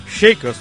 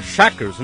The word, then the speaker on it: Shakers: Aliás, o Carlos Vasconcelos mandou uma mensagem ainda agora dizendo que The Shakers deve ser chamado como Los Shakers. E como argentino e uruguai não fala Shakers, fala Shakers, né?